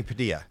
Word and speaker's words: Padilla: Anthony Padilla. – That's right, and you said my last name right.